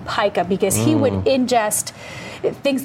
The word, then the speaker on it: pica: I think the eating disorder is known as pica. I don't know if I'm pronouncing that correctly. – It's pica here.